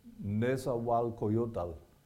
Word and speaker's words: Nezahualcoyotl: Can any of you pronounce that name? Nezahualcoyotl. And so since it's hard to say, even for some Spanish speakers, it's hard for them to say, they just, everybody calls it the Neza Assembly.